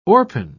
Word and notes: orpine: British English

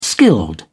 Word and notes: skilled: British English

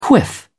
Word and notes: quiff: British English

